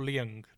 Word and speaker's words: Leung: But Tony Leung, is it Leung or Leung? Leung, sorry. – I don't know if I don't know if you pronounce the G. I don't think it's a hard G, but I'm no expert, man.